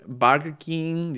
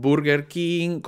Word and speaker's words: Burger King: Porque en vez de decir Burger King, dicen Burger King, como, como que hay que pronunciar las cosas con, de la manera eh, peruana.